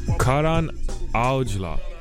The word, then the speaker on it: Karan Aujla: However, then they brought up Karan Ajwal. – Okay. – Karan Ajwal. – Okay. – I will that I'm saying his name right. But he's a Punjabi artist. Karan Aujla. Yes.